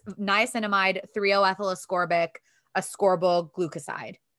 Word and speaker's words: glucoside: It also has a secondary form called ascorbyl glucide. I think it's how you say it. Glucoside.